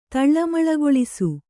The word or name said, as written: ತಳ್ಳಮಳಗೊಳಿಸು